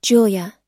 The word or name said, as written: julia